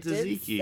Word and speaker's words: tzatziki: She pronounced it tzatziki.